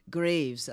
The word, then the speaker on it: Graves: Graves. It's where dead people sleep. In graves.